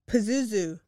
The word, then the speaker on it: Pazuzu: Pazuzu.